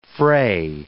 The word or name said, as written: fray